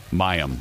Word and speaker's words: Mayim: Mayim? – Mayim.